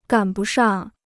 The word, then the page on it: 赶不上: gǎn bù shàng